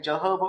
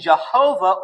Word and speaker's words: Jehovah: And rather than a soft Y, they used a hard J sound. And the same thing for the W, they used a hard V sound. So it became Jehovah.